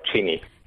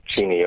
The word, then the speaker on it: Cheney: Yeah, Cheney. Cheney, okay, we asked.